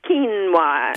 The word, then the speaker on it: quinoa: Yeah, I've heard it pronounced every possible way, but it's presumably pronounced quinoa. – Quinoa. – As in K-E-E-N-O-A. – It looks sort of like quinoa or something, but it's quinoa. You know why? It's a Quechua word.